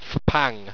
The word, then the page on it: fpang: Owstwecian